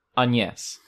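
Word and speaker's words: Agnès: Agnes. Oh, see, I always Agnes. I always use Forvo. – I just clicked the first YouTube hit. Agnes.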